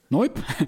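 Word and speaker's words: noyb: En met zijn stichting No Weep, hoe spreek je het uit, jongens? – Noip.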